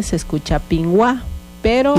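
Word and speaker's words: pimwáj: Pingwa.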